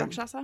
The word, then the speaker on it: Rakshasa: upon encountering the Rakshasa, is that how they say it? – Yeah, the Rakshasa.